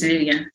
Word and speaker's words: Lilian: No, it's just Lilian. Swahili, okay. Swahili is Lilian, yeah.